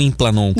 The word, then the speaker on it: Implanon: É implanon ou implanon que pronuncia? – Implanon, tá.